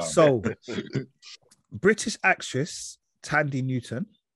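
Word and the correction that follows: Thandie: I think it's pronounced Thandy, but yeah, Thandy. Yeah. – I think it's pronounced that way.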